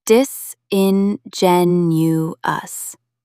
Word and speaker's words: disingenuous: Stress the third syllable. Dis-in-gen-u-us. Try saying it. Disingenuous.